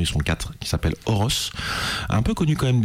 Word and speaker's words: Ojos: Ça s'écrit O-J-O-S et ça se prononce Horos.